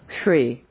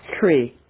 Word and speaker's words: Hri: At the center of the moon standing is his seed syllable, Shri. You can imagine H-R-I. In the nature of white light, Shri.